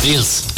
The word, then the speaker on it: Eels: Se escribe E-E-L-S. – Eels.